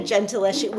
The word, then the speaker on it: Gentileschi: And then I saw I'm going to probably butcher this, the Gentileschi green. – Yeah, it's like Artemisia Gentileschi.